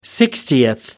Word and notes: sixtieth: American English

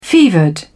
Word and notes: fevered: British English

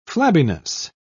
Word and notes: flabbiness: British English

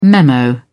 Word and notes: memo: British English

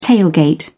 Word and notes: tailgate: British English

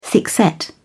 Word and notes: thickset: British English

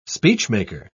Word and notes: speechmaker: British English

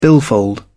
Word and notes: billfold: British English

